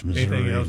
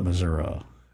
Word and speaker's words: Missouri: And while we appreciate his dissertation, it's Missouri, not Missouri. – I can, it is. – No, it's Missouri. It's Missouri.